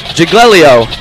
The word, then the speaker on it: Giglio: Jared, the Night Stalker, had plenty of time, fired the ball out to number 11, but Gianni Gigolo. Is that how you're saying his last name? – The Italian Italian pronunciations are tough. Gianni Giglio.